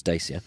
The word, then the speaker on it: Dacia: So we've got from a Dacia, which is how you are meant to pronounce Dacia, to a Bentley, and from a Prius to a Porsche, and lots of things in between.